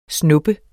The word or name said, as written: snubbe